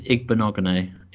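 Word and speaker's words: Igbinoghene: And you've got Trevon Diggs, Noah Igbinogene. We're going to have a bit of a guess. – I think it's Igbinogene is how I've heard it pronounced.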